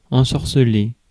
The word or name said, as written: charm